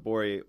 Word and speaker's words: Gborie: Everybody listening, David the G is silent. Gborie, while he was singing that song, was basically pedaling a bike with his hands.